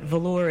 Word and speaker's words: Velours: And don't take this the wrong way, but velour is pronounced without the S, so it's actually velour. And there's a bit of a story about that because it's the French pronunciation.